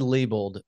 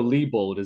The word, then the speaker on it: Leibold: That's the only reason I go Leibold is E-I instead of I-E is how his name is spelled.